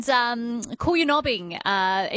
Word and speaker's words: Coolyanobbing: And Coolyanobbing. Ian has messaged through from Geraldton saying, Coolyanobbing, you pronounced it correctly.